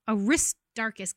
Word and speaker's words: Aristarchus: And um, we're told that they grabbed Paul's associates, who are named Gaius and Aristarch. Uh, Aristarchus, guys, I'm bad at pronouncing these names.